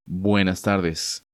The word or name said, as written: Buenas tardes